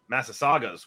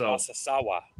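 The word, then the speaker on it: Massasauga: And then in southern Kansas, we got on Massasaugas, which was like extremely badass. – It's actually pronounced Massasawa.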